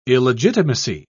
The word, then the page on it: illegitimacy: British English